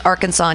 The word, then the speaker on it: Arkansas: Two state names, Arkansas and Kansas, share all but two letters in the exact same order. So why do we pronounce them differently? We can thank the French. Arkansas was named for the French plural of a Native American tribe, while Kansas is the English spelling of a similar one.